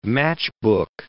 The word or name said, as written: matchbook